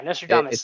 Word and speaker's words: Nostradamus: Nostradamus? What did I say? Yeah, Nostradamus. Forget whatever else. – Like, Nostradamus. – Had like an extra, it's because of the Nostra Dominguez. All right. – An extra emphasis on the wrong syllable.